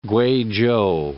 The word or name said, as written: Kweichow